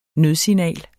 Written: nødsignal

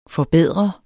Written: FORBEDRENDE

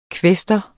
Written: kvæstor

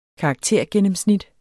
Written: karaktergennemsnit

